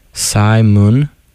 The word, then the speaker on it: Psymun: It's spelled PSYMUN, but it's pronounced like the name, which I did not.